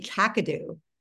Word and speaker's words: Kakadu: Am I saying it right? What is that? I'd never even heard of kakadu. – It's actually kakadu.